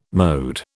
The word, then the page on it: mode: British English